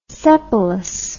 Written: sepalous

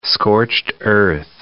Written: scorched-earth

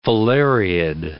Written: filariid

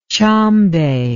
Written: tshombe

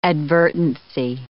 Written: advertency

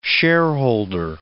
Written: shareholder

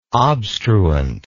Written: obstruent

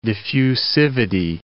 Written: diffusivity

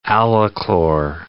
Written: alachlor

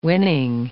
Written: winning

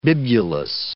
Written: bibulous

